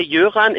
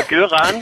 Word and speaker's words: Jöran: Der Name Jöran ist aber schwedisch, wird aber in Schweden mit G geschrieben. Also eigentlich mit G in Schweden. – Ja. – Da das aber dort mit J ausgesprochen wird, haben meine Eltern gedacht, dann müssen wir es in Deutschland mit J schreiben, weil sonst sagt jeder Göran.